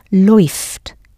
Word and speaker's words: läuft: Right, now for RZS läuft, you'll put an umlaut on the A, so you'll end up with A umlaut U, pronounced oi, as in boy. RZS läuft.